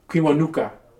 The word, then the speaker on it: Kiwanuka: Kiwanuka.